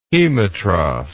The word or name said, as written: hemotroph